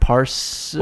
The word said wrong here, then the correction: Placidusax: Oh, Placidusax.